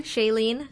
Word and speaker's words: Shailene: First, actress Shailene, am I saying that correctly? – Shailene? – Yes, yeah.